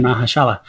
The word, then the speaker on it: Mahershala: With and I've always mispronounced his name, the Maharaja of Ali. – Yes, Mr. Mahershala.